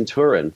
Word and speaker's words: Turin: It was in Turin.